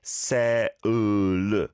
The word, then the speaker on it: Seoul: Halsey Reno's on broad saying, in Brazil, the soul pronunciation doesn't even get this R sound. It's something like se-u-l.